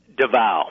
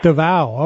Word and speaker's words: Davao: Davao. – Davao.